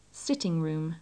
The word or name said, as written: sitting room